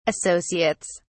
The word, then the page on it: associates: British English